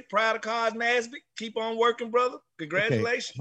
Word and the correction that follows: Nassib: No, it's Carl Nassib.